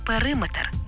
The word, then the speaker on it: периметр: Але периметр, бо не є одиницею вимірювання.